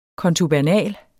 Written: kontubernal

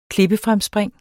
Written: klippefremspring

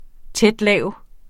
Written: tæt-lav

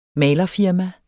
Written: malerfirma

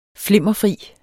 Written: flimmerfri

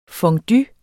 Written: fondue

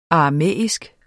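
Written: aramæisk

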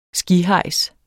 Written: skihejs

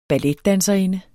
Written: BALLETDANSERINDER

